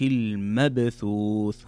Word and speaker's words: المبثوث: طيب كذلك قال الله تعالى في قوله كالفراش المبثوث، هنا عندنا باء ساكنة وتكلمنا عنها سابقا أن الباء الساكنة إذا جاءت في وسط أو نهاية الكلمة يكون الحكم فيها قلقلة، والقلقلة هي اضطراب الصوت نطق الحرف. فتقرأ المبثوث المبثوث، ليست المبثوث المبثوث.